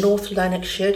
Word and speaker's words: North Lanarkshire: um, North Lanarkshire.